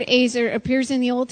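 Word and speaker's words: Azer (עֵזֶר): That word translated helper in the original Hebrew is a word that is pronounced Azer, E-Z-E-R. It's pronounced Azer.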